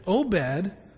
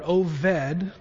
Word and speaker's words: Obed: Obed, or Oved, depending on how you say it, is a word that means servant.